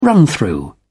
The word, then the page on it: run-through: British English